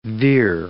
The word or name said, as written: veer